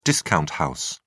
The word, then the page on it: discount house: British English